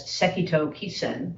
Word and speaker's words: Sekito Kisen: This ancestor, whom we chant as Sekito Kisen, that's the Japanese pronunciation of his name, and I'm going to butcher his Chinese name, he is known as Shitu Jishan in Chinese. Sekito Kisen is what we say when we chant his name in our lineage.